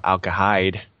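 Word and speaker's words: Al Kharid: Alcahard rhymes with hide, so Alcahide.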